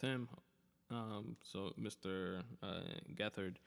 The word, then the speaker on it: Gethard: it's spelled get hard uh, his last name um, but it's uh, pronounced gethard um,